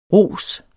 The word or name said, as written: ros